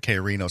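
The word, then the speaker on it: Krino: But from what I understand and what the guy was saying, he was pronouncing it K Reno. So it's K-R-I-N-O. – Oh, it is R-I, though.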